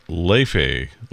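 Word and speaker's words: Laufey: We had Broadway star Joseph Leobury with her name is pronounced Leve. Leve, kind of.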